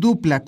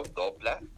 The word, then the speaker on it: Doopla: Es este, se escribe con doble O, dopla, pero se pronuncia dupla.